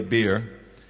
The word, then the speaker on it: Beer: They didn't really go to get a beer. It's pronounced Beer, and it means the well.